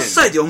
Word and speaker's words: 獺祭: 名前がね、獺祭ってそれ読めねん、獺祭って読むんですね、